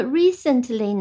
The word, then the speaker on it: recently: 那么 recently 呢，它的一个读音的重音是在第一个音节 re，这个 e 发成长音 e re recent cent 呢，这个不是 s 是 c c e n t cent c e n t cent 再加上 l y l recently，所以我们再拼一遍 r e c e n t recent。